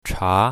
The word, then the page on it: cha2: mandarin